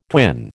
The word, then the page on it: twin: American English